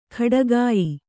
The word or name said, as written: ಖಡಗಾಯಿ